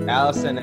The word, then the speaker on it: Alison: So much, Alison and Andrea, right? – Yeah.